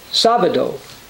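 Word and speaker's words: sabado (Σάββατο): To this day, the Greek word for Saturday is pronounced sabado, as in the case in many other languages.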